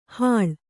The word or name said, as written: ಹೀಳು